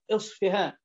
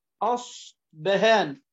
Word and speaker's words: أصفهان: ثم قال قوله الأصفهاني أو الأصفهاني نسبة لأصفهان بفتح الهمزة وكسرها أصفهان والفتح أفصح، وبالفاء والباء ينفع تقول أصفهان أصبهان، وهي بلدة بالعجم، وأصلها في اللغة الأعجمية بالباء مشوبة بالفاء أصفهان، ثم عربتها العرب فنطقوا بالباء تارة أصبهان وبالفاء تارة أخرى أصفهان.